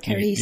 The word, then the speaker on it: Carice: How do you say her name? – Yeah, Carice. – You got it. Yeah, Carice Van Houten.